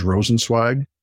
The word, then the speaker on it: Rosenzweig: By the way, my last name is Rosenzweig.